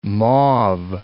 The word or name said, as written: mauve